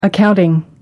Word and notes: accounting: American English

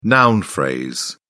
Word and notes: noun phrase: British English

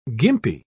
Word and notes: gimpy: British English

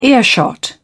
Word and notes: earshot: British English

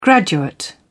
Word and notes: graduate: British English

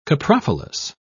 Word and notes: coprophilous: British English

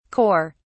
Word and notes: kore: British English

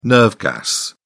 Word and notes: nerve gas: British English

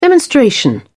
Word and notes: demonstration: British English

